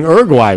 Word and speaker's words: Uruguay: Wow, I don't even know if I'm saying Uruguay right. Am I saying it right? – Yeah, you're saying it right.